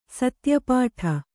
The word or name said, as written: ಸತ್ಯಪಾಠ